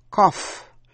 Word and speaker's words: cough: Cough, nghĩa là ho. G-H phát âm là f. Cough drops là kẹo ho. O-U-G-H phát âm là ow trong chữ bow, B-O-U-G-H, bow là cành cây.